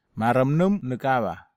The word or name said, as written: maramnum nekava